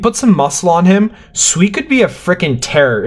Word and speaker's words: Sweat: I'm going to go with Sweat for now, but I think this pick will change a few times. – I know it's spelled Sweat, but it's pronounced Sweet.